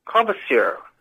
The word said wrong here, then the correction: Courvoisier: Oh, Courvoisier. – Courvoisier.